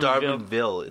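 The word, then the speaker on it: D'Arbanville: Darbonville, it's pronounced.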